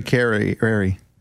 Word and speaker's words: Cavallari: Well, because it rhymes. Very Cavallari.